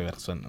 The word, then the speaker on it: Bergson: No sé cómo se pronunciará, tú que eres más francés. ¿Cómo se pronuncia? Henri Bergson, ¿sería? – Ya, ¿Henri Bergson? – Sí, ya, Bergson. Ah, sí. No sé, yo le digo Bergson.